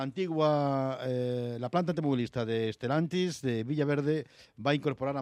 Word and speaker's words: Stellantis: Por cierto, la planta automovilística de Stellantis de Villaverde, ¿se pronuncia así? ¿Stellantis? – Stellantis, eso es.